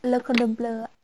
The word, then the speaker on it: Le Cordon Bleu: Le Cordon Bleu ạ.